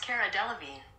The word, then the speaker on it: Cara Delevingne: That's pronounced Cara Delevingne.